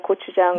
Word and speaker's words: gochujang: If you like spicy, the Korean traditional sauce is the gochujang, which is a pepper paste.